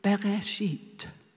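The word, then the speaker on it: Bereshit (בראשית): And the way you pronounce that word is Bereshit.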